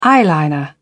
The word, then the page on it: eyeliner: British English